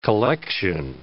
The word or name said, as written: collection